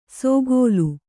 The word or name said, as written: ಸೋಗೋಲು